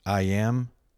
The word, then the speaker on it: EYEAM: How else can you explain the partnership and collaboration of Atlanta musicians IAM and Joel Hoffman? IAM, pronounced like I am, but spelled out E-Y-E-A-M, is a 20-something-year-old African-American hip-hop and R&B artist from the South.